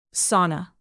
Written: sauna

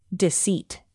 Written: deceit

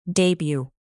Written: debut

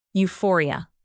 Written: euphoria

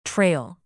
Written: trail

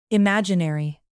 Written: imaginary

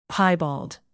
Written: piebald

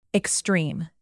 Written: extreme

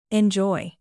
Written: enjoy

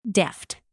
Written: deft

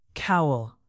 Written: cowl

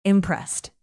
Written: impressed

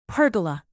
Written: pergola

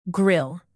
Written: grille

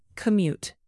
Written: commute

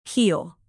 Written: heel

